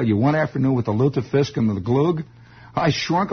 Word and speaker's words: lutefisk: That is lutefisk, L-U-T-E, with the little umlauts and little things, little crystal balls and chandeliers over the U there. Lutefisk. Lutefisk. The way it's pronounced.